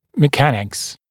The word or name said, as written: mechanics